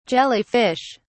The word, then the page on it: jelly fish: British English